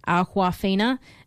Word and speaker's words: Awkwafina: So the drink is called Awkwafina, but Awkwafina. Anyway, it's spelt almost like awkward, and she's kind of awkward, and she thought it was a funny name, so that she kind of went with it.